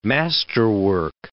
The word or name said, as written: masterwork